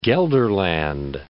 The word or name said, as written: Gelderland